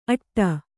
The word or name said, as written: ಅಟ್ಟಿ